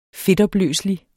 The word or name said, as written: fedtopløselig